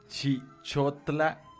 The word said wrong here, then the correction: Chilchotla: Chilchotla, Chilchotla se pronuncia, Chilchotla, Puebla.